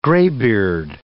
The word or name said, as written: graybeard